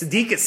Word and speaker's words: Sudeikis: Like, Sudeikis? – Sudeikis. – Sudeikis and Makulkin. They do listen, because those are other people. – Yep. – There's some guy named Sudeikis who listens, and a Makulkin who listens.